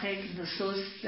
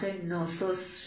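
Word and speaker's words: Knossos: خارج از مواردی که برمیگرده به اون افسانه اودیسه و برمیگرده به این واقعیت کاخ گنوسوس در این کاف، این واژه خیلی کم تلفظ میشه، کاخ نوسوس شاید حتی یک نوسوس تلفظ میشه.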